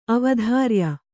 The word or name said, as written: ಅವಧಾರ್ಯ